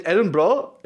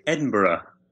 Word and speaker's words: Edinburgh: Is it Edinburgh? Did I totally mispronounce it? – We would say Edinburgh, which I realize Americans have real difficulty with, but Edinburgh. – Edinburgh.